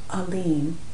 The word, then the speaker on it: Alim: And the wazifa, or sacred word that was given prior to the reading of this paper, was Alim, A-L-I-M, but pronounced A-L-E-E-M, Alim.